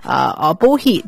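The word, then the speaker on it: Bohea: 这里呢就可能就是武夷红茶，这里武夷红茶 Bohi 就是武夷在闽南语里面发音，光是武夷红茶在这个 342 箱里面就是占了 240箱， 70%以上。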